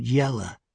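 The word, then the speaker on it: Ylla: Y-L-L-A. I knew that name, and although he pronounced it Yella, I knew the correct spelling, and I could see by his eyes that he knew I knew.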